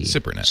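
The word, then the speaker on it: SIPRNet: I was calling it Cypernet, Cyprnet. And he sort of breaks it up phonetically, and he says it's pronounced SIP, S-I-P hyphen P-E-R hyphen N-E-T. – Cypernet.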